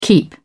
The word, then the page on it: keep: British English